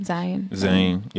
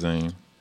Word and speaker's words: Zayn: Whatever you say his name. Zayn. – Yeah, I think it's Zayn.